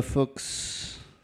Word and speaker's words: Fuchs: Before that was Arthur Russell, Jim Stewart, Daniel Muchnick, Eva Kohler, Megan Gwilt, Jess Gagne, Michael Cohen, Alexis Dibb, Emma Fuchs, rhymes with books,